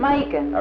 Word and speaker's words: Macon: And there's another, and these I don't know if you know, they're in middle Georgia, starts with an M, rhymes with bacon, starts with an M. – Macon. – Okay. And yeah, I had an aunt there in Macon, Georgia.